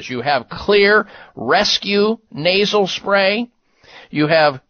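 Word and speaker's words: Xlear: Just remember, Clear, spelled with an X, X-L-E-A-R, nasal sprays, and a variety of them at finer drug and grocery stores and health food stores nationwide.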